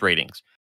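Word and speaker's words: Rtings: It's ratings without the letter A. So it looks like Ratings. – Ratings. Okay. – But it's pronounced ratings.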